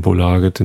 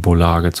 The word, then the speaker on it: Bolaget: System Bolaget, so heißt der Shop. Oder Bulaget heißt das, wird glaube ich richtig ausgesprochen.